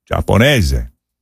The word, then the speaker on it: Giapponese: Giapponese. And that's spelled G-I-A-P-P-O-N-E-S-E.